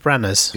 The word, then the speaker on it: Branagh: Against Kevin Bragna? Is that how you say it? – Kenneth Branagh's. – Yes, Branagh's.